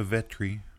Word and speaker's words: Vetri: played by Victoria Vetri. – Yep. – If that name sounds familiar, well. – It's because it rhymes with Petri, and I assumed that she was related to the fictional character played by Dick Van Dyke.